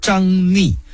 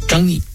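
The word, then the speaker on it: 张嶷: 为首的蜀将乃是张逆，以前介绍过的，这个字有两个读音，一个念逆，一个念疑，上边一个山字，下边一个怀疑的疑，张逆。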